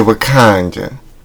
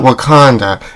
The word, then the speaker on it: Wakanda: No, you gotta say it like that, right? It's not Wakanda. – It's Wakanda.